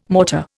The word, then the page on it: mortar: Indian